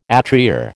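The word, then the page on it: étrier: American English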